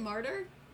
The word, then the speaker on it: martyr: I pronounced mater wrong. – What do you mean you pronounced mater wrong? – I didn't... Is that how you pronounce it? Is it martyr or mater? – Martyr. – M-A-Y-T. Yeah. – Oh, yeah, martyr. We're leaving that in. It's martyr. It's mater now.